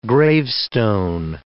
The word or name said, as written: gravestone